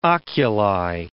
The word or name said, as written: oculi